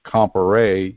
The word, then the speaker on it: Comparet: By the way, Bertrand Comparé, his last name is French. That's why it's pronounced Comparé, even though it's spelled C-O-M-P-A-R-E-T. In the French language, which I took 4 years of in high school, in the French language, E-T at the end of the word is pronounced A.